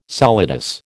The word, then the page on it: solidus: American English